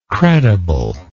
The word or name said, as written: credibleness